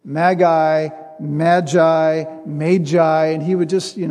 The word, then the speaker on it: Magi: I think it's Magi. That's the original pronunciation of the word. The Greek word for it is magos, the plural magoi.